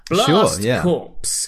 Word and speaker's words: Blast Corps: So at Keep Playing Pod, if you know if it's Blast Corps or Blast Corps, is the S a silent S? – No, the P. That would be. – The P. Is it a silent P? – Yeah.